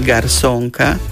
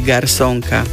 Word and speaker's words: garzónka: Slovo garzónka alebo garzónka sa môže vyslovovať dvoma spôsobmi, ale píše sa len jedným spôsobom, a to zo s. Teda píšeme garzónka, ale prirodzene môžeme vysloviť aj garzónka, aj garsónka. Je to byt, ktorý pozostáva v podstate z jednej takej miestnosti s príslušenstvom. Maličký priestor, v ktorom sa samozrejme dá existovať a bývať. Takže garsónka alebo garzónka, tá výslovnosť je dvojaká, ale pravopis je zo s.